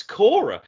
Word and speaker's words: Cora: I'd watch a Q&A with Alex Cara. – With who? Oh, Alex Cora. Okay, geez. Well, once you pronounce it right, I've got some idea.